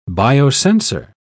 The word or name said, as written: biosensor